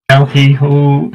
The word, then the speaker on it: El Hijo: El Hijo, I'm awful of pronunciations, as I think everybody would know that.